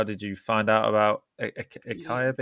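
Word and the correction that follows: açaí: Beans, am I pronouncing that right? – Yeah. I think they call it acai berry, okay. No, we call it acai berry.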